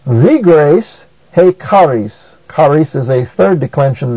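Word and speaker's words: he charis (ἡ χάρις): Also notice that hey is a proclitic. That is, it goes so closely with the word that follows as to have no accent of its own. Therefore, it is pronounced he charis.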